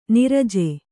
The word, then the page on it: ನಿರಜೆ: niraje